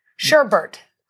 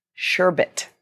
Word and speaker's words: sherbet: How do you say S-H-E-R? – Sherbert. – It's sherbet. There's not another R in there.